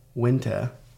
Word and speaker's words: Uinta: there's one, everybody mispronounces it only because I know, because I was in the Uinta Mountain Range. – U-I-N-T-A? – Yeah, yeah, it's weird. It's called Uinta. And Uinta? They can do... – Spell it for me again? – U-I-N-T-A, is it, right? – Yeah.